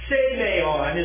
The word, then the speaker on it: semeion: Now then, the second one, brethren, semeion is the way you would pronounce that. Semeion is the Greek term.